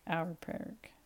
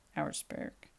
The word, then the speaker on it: Auersperg: I hope I'm saying that right. It's Auersperg. Auersperg.